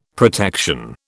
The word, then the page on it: protection: British English